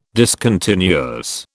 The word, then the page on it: discontinuous: British English